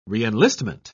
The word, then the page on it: reenlistment: British English